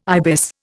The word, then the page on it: ibis: Indian